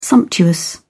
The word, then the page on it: sumptuous: British English